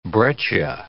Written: breccia

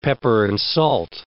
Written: pepper-and-salt

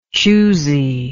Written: choosiness

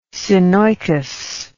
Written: synoicous

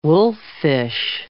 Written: wolffish

